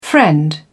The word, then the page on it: friend: American English